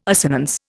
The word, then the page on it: assonance: Indian